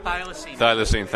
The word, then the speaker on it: thylacine: Thioclene. – Is that how you pronounce it? – Thylacine. Thank you very much. – Thylacine. I'm from the South, so it comes out Thioclene.